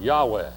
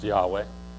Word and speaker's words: Yahweh: We think it's pronounced Yahweh. We don't know for sure because we don't know the actual vowels that are in there.